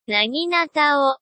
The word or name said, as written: naginatao